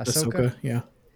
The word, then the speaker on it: Ahsoka: Ahsoka. Is that how you say it? Ahsoka? – Ahsoka, yeah.